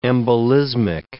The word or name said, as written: embolismic